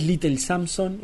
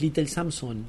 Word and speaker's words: Little Samson: Siempre la entonación va en la última sílaba. Esdrújulas, abstenerse. – Sí, exacto. No es Little Samson, es Little Samson.